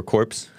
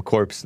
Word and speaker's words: Corps: Guess the new corps, or corpse. Obama called him corpse, the Marine Corps. Didn't he say that? It's pronounced corps, right? Marine Corps.